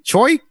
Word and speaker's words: Choix: C-H-O-I-X, right? – Yeah. – How do you say that? – That's chicks. – No. That's choice. – Choy. It's choy.